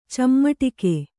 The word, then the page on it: ಚಿಮುಟಿಗೆ: cimuṭige